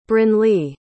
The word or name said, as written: brynlee